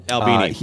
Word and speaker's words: Albini: And the man himself, Steve, I think you say his name, Steve Albini. – Albini.